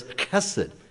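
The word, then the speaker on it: chesed (חסד): But the Hebrew word is chesed. In fact, we don't even have, you know, it's like the German Bach, you know, it begins with that, you know, we'd have a hard time even pronouncing chesed, chesed.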